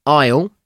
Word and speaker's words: isle: Five words with hidden S words, silent S words. Isle.